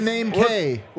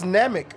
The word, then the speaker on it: Namek: Well, Big it's, name K. Well, no, it's it's Namek. Big Namek from Dragon Ball Z.